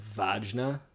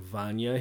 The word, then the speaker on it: Vajna: Sometimes they talk about this producer named Andrew Vajna. Mm-hmm. Vajna. Mm-hmm. It's actually pronounced Andrew Andrew Vanya.